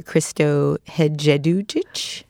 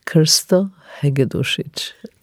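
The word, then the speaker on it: Krsto Hegedušić: You studied with the painter Christo Hedzedujic? – Oh, that's really badly pronounced. Let me try to tell you. Christo Hedzedujic. Difficult Slavic names.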